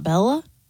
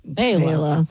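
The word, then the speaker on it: Béla: Born in 1958, the great banjo player, Mr. Fleck, first name B-E-L-A. – B-E-L-A. It's not Bella? – It's Bela. – Bela.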